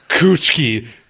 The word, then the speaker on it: Kuchiki: I'm sorry, how the fuck did you just pronounce his name? – I don't even know. Byakugan? – It is Byakuya Kuchiki, sir. Kuchiki. And I will thank you to pronounce it properly.